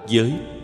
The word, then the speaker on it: giới: Gặp những chữ cần kiên cử thì phải nên để ý như giái, đọc là giới.